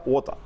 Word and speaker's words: Water: Ada Clear Water se escribe. Se pronuncia Wota.